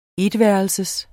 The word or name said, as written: etværelses